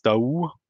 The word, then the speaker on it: Daoust: Alexis Daou wird der, glaube ich, ausgesprochen. Daoust geschrieben äh, von den äh, Eispiraten Krimitschau.